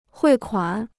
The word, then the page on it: 汇款: huì kuǎn